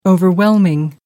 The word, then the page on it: overwhelming: American English